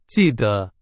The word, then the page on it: 记得: Chinese